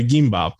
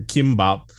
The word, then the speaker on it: gimbap: Gimbap, right? The food, or kimbap. Like, it's got like more of a G sound to it, but it's spelled with a K in romanization.